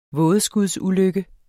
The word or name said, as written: vådeskudsulykke